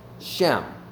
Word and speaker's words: Shem: And the word name from the Old Testament is a Hebrew word, Shem. S-H-E-M, actually kind of pronounced shame.